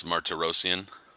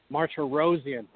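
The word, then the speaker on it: Martirosyan: I mean, GGG announcing his fight against Mark Tarosian. How do you say it? How do you say his name? I struggle with these Armenian names. – Vanis Martirosian. – Martirosian. Thank you.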